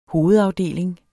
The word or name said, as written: hovedafdeling